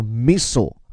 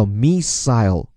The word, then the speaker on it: missile: 后面这个导弹发音，各位请注意，在美音当中叫 missile。而在英音当中呢，叫 missile，关键是第二个字母 i 的发音不同。再说一遍哈，英国人是发作 missile，而美国人是 missile。